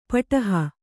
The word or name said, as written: ಪುಡೆಹ